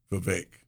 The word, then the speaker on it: Vivek: Yeah, it rhymes with cake, Vivek.